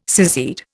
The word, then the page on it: secede: Indian